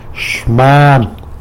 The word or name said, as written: xman